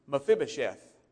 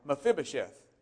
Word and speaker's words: Mephibosheth: And we find out that his name was Mephibosheth. Mephibosheth. You have to practice a little while to say that properly. Mephibosheth.